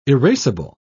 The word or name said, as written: erasable